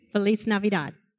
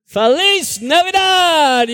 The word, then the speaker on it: Feliz Navidad: How do you say Merry Christmas in Spanish? No heads. No heads. – Feliz Navidad. – Feliz Navidad. Yes.